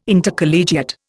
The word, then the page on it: Intercollegiate: Indian